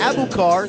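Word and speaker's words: Aboubacar: It's A-B-O-U-B-A-C-A-R, pronounced Abukar, and Siss is C-I-S-S-E, pronounced Siss.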